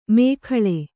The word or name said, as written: ಮೇಖಳೆ